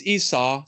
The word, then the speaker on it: Esau: By the way, I've been told I say Esau weird. I always pronounce the U. It's Esau. So forgive my Esau, like a sow of a pig.